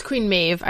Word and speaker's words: Maeve: Mab. It's Queen Maeve. I said it wrong.